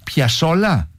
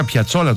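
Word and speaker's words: Piazzolla: Και Άστορ Πιασόλα. – Σωστότατα. – Μισό λεπτό. Το άκουσα να το λέει. Εγώ τον έλεγα Πιατσόλα τόσο καιρό.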